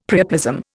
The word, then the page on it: priapism: Indian